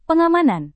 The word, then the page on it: pengamanan: Indonesian